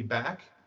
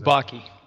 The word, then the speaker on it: Bakke: Back, which was decided in uh, 1978. Am I pronouncing that correct? I've always just said Back. Is Bakke. Yeah. Ah, Bakke. Thanks.